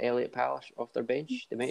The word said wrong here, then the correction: Parish: I mispronounced Parish. Through an L on the instead of the R. – And what was it? Devine? – Rhys Devine or Elliot Parish off the bench.